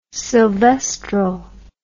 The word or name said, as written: sylvestral